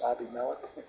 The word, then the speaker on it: Abimelech: Abimelech, it should be pronounced Abimelech, my father is king.